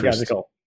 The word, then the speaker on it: pedagogical: And they claimed in the filings that they had a pedagogical, if I said that right, pedagogical interest. – Pedagogical. – Yes, thank you.